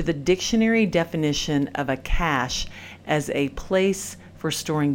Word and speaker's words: Cache: It's spelled C-A-C-H-E, Cache.